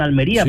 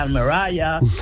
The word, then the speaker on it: Almería: Pepper, él recordaba con todas las palabras Almería, y fíjate que la ha pronunciado bien, Almería, sí, porque sí, un extranjero sí diría Almeraya,